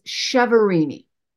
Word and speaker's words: Chiaverini: So the American pronunciation is Chiaverini.